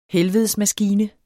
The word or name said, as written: helvedesmaskine